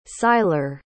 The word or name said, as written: Syler